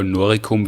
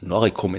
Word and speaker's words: Noricum: Ja, das ist umstritten, aber ich glaube wahrscheinlich in dem Fall Noricum wird man es mit K aussprechen.